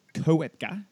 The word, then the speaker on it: Koepka: I thought it was Koepka, like the O was that, but it's silent, so it's just Koepka. – Koepka. Not Chopra like I was saying. – Nah, Brooks Koepka.